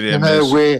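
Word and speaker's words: Hermès: Hermes or something or whatever. I never know how to pronounce it. – No, you're quite right with Hermes.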